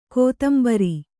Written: ಕೊತ್ತುಂಬುರಿ

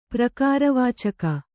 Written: ಪ್ರಕಾರವಾಚಕ

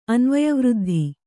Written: ಅನ್ವಯವೃದ್ಧಿ

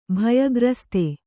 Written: ಭಯಗ್ರಸ್ತ

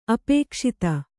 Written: ಅಪಕ್ಷಾತಿ

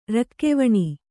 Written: ರಕ್ಕೆವಣಿ

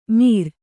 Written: ಮೀರ್

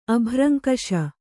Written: ಅಭ್ರಂಕಷ

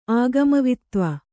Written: ಆಗಮವಿತ್ತ್ವ

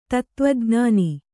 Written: ಧಾತುವಿಜ್ಞಾನ

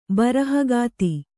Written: ಬರಹಗಾತಿ

